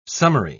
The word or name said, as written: summary